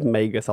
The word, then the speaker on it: Magus: I'm going to actually go with Magus. I like the hard G. – I believe it is Magus.